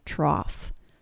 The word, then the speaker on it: trough: The vowel sound in the word cough is the AW sound. The only other word that has the AW sound plus an F sound pronunciation is the word trough.